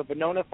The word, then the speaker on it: Venona: But in the end, the Russians in the 1990s, they released the files, which is, again, I always mispronounce the Verona files, the Venona files.